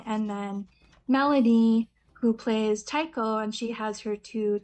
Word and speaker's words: taiko: Taiko? Taiko? – Yeah.